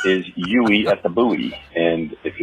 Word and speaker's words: Uey: It's spelled U-E-Y at the Buoy.